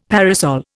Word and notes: parasol: Indian

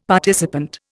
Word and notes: participant: Indian

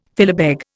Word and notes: filibeg: Indian

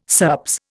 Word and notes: SERPS: Indian

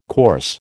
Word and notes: coarse: American English

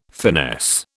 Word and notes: finesse: British English

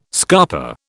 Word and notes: scarper: British English

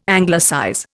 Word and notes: Anglicize: Indian

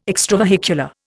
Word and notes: extravehicular: Indian

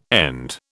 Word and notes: end: British English

